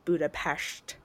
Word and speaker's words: Budapest: I think if you're native to that area or, you know, that part of the world, you would, you know, that's, you would probably pronounce it Budapest or however, right. You know, like that, I think is like what we would say is the accurate pronunciation.